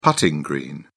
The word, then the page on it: putting green: British English